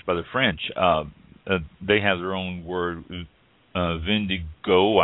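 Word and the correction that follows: vin de goutte: I believe it's pronounced, G-O-U-T-T-E. Oh, there we're going to get somebody writing this, Mike. I said I pronounced it wrong. Vindigout.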